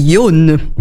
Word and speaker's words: Yon: Donc, cela se dit Yon. – Bien sûr. – Tout à fait. En bon japonais. Et ça correspond donc au chiffre 4.